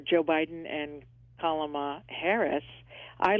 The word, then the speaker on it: Kamala: Just think of comma, like a comma, an explanation comma. It's Kamala. – Gotcha. Kamala.